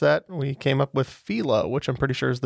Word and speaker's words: phyllo: And today I learned that that word is properly pronounced phyllo. I've called it phyllo my entire life, but it is correct.